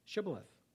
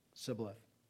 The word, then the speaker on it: Shibboleth: When he said no, they said to him, say Shibboleth. And he said Sibboleth, for he could not pronounce it right.